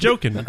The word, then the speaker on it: Joaquin: I'm like, oh, it's Joaquin.